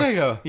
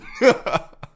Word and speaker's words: Sega: Or, Sega. Yeah, say it like you would say sorry. Sega.